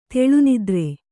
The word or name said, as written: ತೆಳುನಿದ್ರೆ